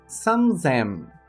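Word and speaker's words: sanzen (三千): Para o número 3000, sanzen. Sanzen. Com a leitura C aplicando tenten, alterando sua pronúncia para Z.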